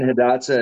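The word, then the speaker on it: Hidatsa: 10 years ago, I'm interviewing Missouri River Resources and the MHA Nation, Mandan Hadicera. How do you pronounce that? Sorry, Pop. Mandan, Hadatsa, and Arikara.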